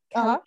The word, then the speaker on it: Kalilah: And her name was a challenge for me in the beginning, but her name is Kalila. Kalila, uh-huh. And it's spelled Kalilah.